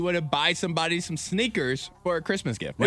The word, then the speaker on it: SNKRS: It's called the Sneakers app. It's spelled sneakers without the vowels. So S-N-K-R-S.